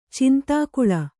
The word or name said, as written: ಚಂದಕಲ